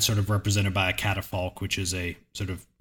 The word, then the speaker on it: catafalque: Before me was a catafalque. Is that the right way to pronounce that? Catafalque? Hello? – Catafalque. – Catafalque. All right.